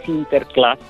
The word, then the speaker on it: Sinterklaas: donde aparece Sinterklaas, todavía es un personaje secundario, pero ya mm, lo pronuncia como Klaus, Sinterklaas, Santa, Santa Claus, ¿no?